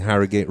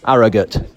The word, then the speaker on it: Harrogate: Apparently we've been saying Harrogate wrong, and a listener, a friend of the podcast who was on our bike ride the other day, gave us the correct pronunciation. Let's just hear what it is.